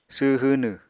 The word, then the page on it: sww hwnw: apatani